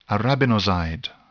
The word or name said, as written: arabinoside